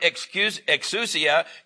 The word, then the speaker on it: exousia: He has given him, and the word authority is, and I've written this down a little note here, but the word, exousia, it's E-X-O-U-S-I-A, and it's pronounced exousia.